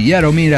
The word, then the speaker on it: Jaromir: Bueno, una bebida que me invitó Jaromir, así se pronuncia, mi amigo checo, que apenas habla inglés.